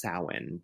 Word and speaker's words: Samhain: Well, it's funny because it's spelled like the letters M and H next to each other. I believe in Welsh or Celtic pronunciation creates the W sound. So that's how it's pronounced, Samhain, but it's spelled S-A-M-H-A-I-N.